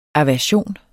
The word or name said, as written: AVERSIONER